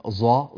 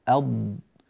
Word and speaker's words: ض: فنحن نضبط الصواب، فالضاد هكذا تنطق أض أض أض